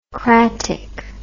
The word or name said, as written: cratic